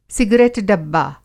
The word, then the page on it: सिगरेट-डब्बा: Hindi